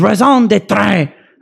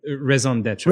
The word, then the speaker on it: raison d'être: Part of why I'm skeptical is the mythic rating theorycraft community is, to a first order approximation, a huge social engine whose raison d'être, or however you say raison that, d'être. How do you say it? French me. – Raison d'être.